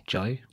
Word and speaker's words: Jai: Do you say Jai or Jai? – Jai.